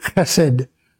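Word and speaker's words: chesed (חסד): K-E-E-K-E. Tov. T-O-V. Lay. L-E. Olam. O-L-A-W-M. And then the word chesed. That H with a dot under it. E-S-E-D.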